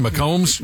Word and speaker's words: McCombs: When we thought we killed old Red Mahomes. How do you say his name? McCombs? – McCombs.